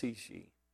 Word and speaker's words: Cixi: She was called the Empress Dowager, and I think it's pronounced Cixi, C-I-X-I.